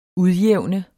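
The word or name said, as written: UDJÆVNET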